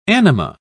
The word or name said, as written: anima